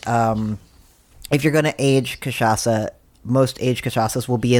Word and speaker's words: cachaça: How is it different than cachaça? So, and first you have to learn how to pronounce cachaça, because it doesn't look like that.